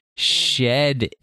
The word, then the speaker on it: Shedinja: It is Sheninja. – I agree. – Well, except properly pronounced, it's Shedinja. Shedinja. There's a D in there.